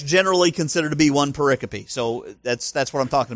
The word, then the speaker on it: pericope: Pericope. It's spelled P-E-R-I, peri, and then C-O-P-E, cope. It's not pericope, it's pericope.